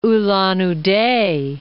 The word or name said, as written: Ulan-Ude